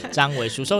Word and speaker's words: 張維紓: 欢迎上 OpenTix 两厅院文化生活，可以搜寻零到一，就是那个零 dash 一，或者是搜寻张维书，维是四维八德的维，书是现在很常出现的书困金的书，所以不可以念错，念成语。 – 书困计划的书，OK，张维书，搜寻张维书也可以找到相关的资讯。